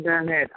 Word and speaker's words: Garnett: Ambassador Jolene Garnett, I'm speaking.